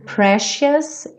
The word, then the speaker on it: precious: Isn't she precious? Esse C com som de X. Precious.